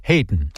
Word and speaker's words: Haydn: British composer Haydn Wood, his first name is spelled like the name of the noted composer Joseph Haydn, H-A-Y-D-N, but his mother decided to pronounce it Haydn.